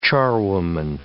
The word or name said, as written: charwoman